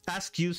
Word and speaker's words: Askew: Se trataba de Dykes Askew Simmons. Se escribe eh, Dykes con Y, con K. Dykes Askew es A-S-K-E-W Simmons con doble M.